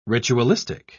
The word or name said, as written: ritualistic